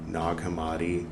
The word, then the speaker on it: Nag Hammadi: So it's spelled like nag, but pronounced more like eggnog, right? Nag Hammadi, Nag Champa.